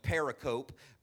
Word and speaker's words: pericope: That's a funny word because it's spelled pericope, but it's pronounced pericope.